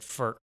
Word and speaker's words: Furch: And he's like, well, he goes, that's how I say it too, but the other guy pronounces it, I think he said Furk, but he kind of does something, you know, phlegmy with the C-H, at the end.